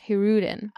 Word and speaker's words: Hirudin: I didn't know how to pronounce it either, and it's pronounced Hirudin.